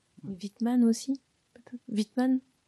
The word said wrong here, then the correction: Whitman: C'est un auteur, enfin je le prononce peut-être mal. – Walt Whitman. Walt Whitman.